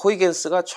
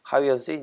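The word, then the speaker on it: Huygens: 이제 호이겐스가 처음 그 토성의 고리를 발견한 네덜란드의 그 천문학자 하위언스 이제 미국식 발음이라고 이제 말씀드렸던 것처럼 아직 뭐 기억하시겠죠?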